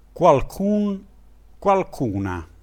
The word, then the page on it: qualcun: dialetto